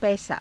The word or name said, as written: paisaa